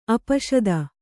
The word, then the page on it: ಆಪಸಾತು: āpasātu